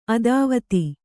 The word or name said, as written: ಅತಿವಾದಿ